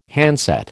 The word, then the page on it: handset: American English